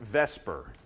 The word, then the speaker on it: VSEPR: And the theory that's used to predict the shapes that are going to form is called valence shell electron pair repulsion, which is abbreviated VSEPR, and it's pronounced VSEPR.